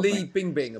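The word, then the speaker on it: Li Bingbing: Is it Fan Bing Bing? Am I Bing Bing Lee? I'm going to get the name wrong. This is terrible. – Lee Bing Bing, apparently.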